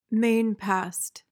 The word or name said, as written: mainpast